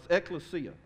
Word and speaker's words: ekklesia: And actually the word for church here is the Greek word ekklesiasi. It's ekklesia. I said that wrong. Yes, ekklesia. And ecclesia always refers to a gathering of people united by a common identity and a purpose.